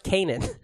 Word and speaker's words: Kanan: No, it's not canon. – Oh, it got retconned? – Yeah. – I think you guys are... it's pronounced Kanan.